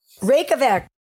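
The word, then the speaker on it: Reykjavík: Reykjavik.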